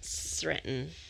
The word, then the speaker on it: Sretten: It is S-R-E-T-T-E-N, Sretton, but it's Netters backwards, because my nickname was Taken, so I went backwards.